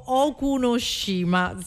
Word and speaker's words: Okunoshima: ma c'è anche a Okunoshima, si dovrebbe pronunciare così, comunque in Giappone.